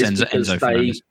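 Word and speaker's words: Enzo Fernández: Enzo Fernandez, said that right. – Yes, Enzo Fernandez.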